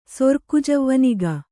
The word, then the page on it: ಸೊರ್ಕುಜವ್ವನಿಗ: Kannada